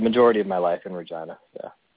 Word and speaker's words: Regina: I am in Regina, Saskatchewan.